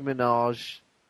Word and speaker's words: Minaj: Nicki Minaj.